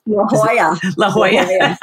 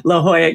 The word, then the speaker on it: La Jolla: Hope I'm pronouncing that correct. – La Jolla. – I knew I was butchering that one. La Jolla, California.